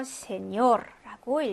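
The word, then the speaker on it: señor: 그렇기 때문에 맨 끝음절에 강세가 오게 됩니다. 그래서 세뇨르라고 읽어주시면 되겠죠.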